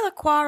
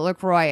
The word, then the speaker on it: LaCroix: slogans that rhyme with Croy. So I'm like, oh, okay. That is emphasis. – Oh boy, LaCroix.